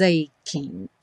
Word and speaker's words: aching: Então, though your heart is aching, is aching. Juntamos o is com aching e o G do aching, como do breaking, como do shining, não vai ser pronunciado.